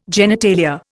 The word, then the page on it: genitalia: Indian